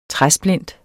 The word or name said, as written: træsplint